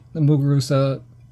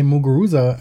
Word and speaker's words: Muguruza: French Open champion Muguruza traveled to Mallorca to play the first year of this grass tournament.